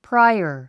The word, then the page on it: prior: práiə r; プライア